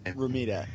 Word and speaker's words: Romita: Romita. – Is it Romita? – Yeah.